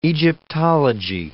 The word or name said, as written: Egyptology